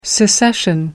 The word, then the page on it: secession: British English